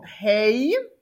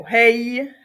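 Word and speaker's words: He (הא): Tak, další je písmenko hej. He nebo hej, čte se jako h, jo, žádnej dageš nemá a je to hrdelnice, s tím se taky setkáme, jo, čili guturála, vyslovuje se jako z hrdla, hej,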